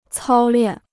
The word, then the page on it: 操练: cāo liàn